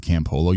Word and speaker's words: Campolo: Yeah, well, you said my name, Stephen Campolo.